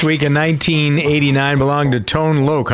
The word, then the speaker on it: Tone Loc: I had pronunciation lessons during the break. Right. Made sure I got Tone Loc right.